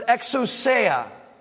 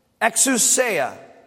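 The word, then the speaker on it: exousia (ἐξουσία): Authority in the Greek is a word that you pronounce exousia, exousia.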